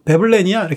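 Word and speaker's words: Veblen: 그런데 이제 베블렌에 대해서 막 얘기를 하는데 조별 발표 같은 걸 하는데 체드라는 남자애가 발표하는데 베블렌을 잘 모르니까 웹원이라고 발음을 하게 됩니다. 그러니까 그러면 그냥 넘어가면 되는데 그걸 굳이 또 베블렌이야 이렇게 딱 고쳐서 집어주는 그런 스타일의 사람인 거예요.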